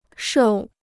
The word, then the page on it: 瘦: shòu